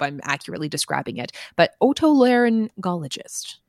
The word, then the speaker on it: otolaryngologist: It is otolaryngologist. – Otolaryngologist. – That's, yeah. So I wasn't sure if it was like a hard G in the first G, but it is otolaryngologist, unless the pronouncer videos have lied to me, which is possible.